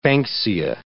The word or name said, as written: banksia